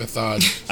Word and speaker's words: Méthode: How do you say it? – Méthode.